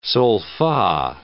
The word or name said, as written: sol-fa